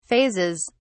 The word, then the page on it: phases: British English